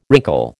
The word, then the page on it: wrinkle: American English